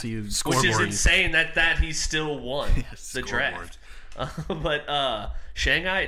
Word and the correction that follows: Shang-Chi: Well, how do you pronounce it? You said Shanghai? You said Shanghai? – Shang-Chi.